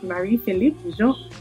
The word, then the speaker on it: Marie-Philippe Jean: I'm here today with Marie-Philippe Jean.